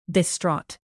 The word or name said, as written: distraught